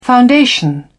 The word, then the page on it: foundation: British English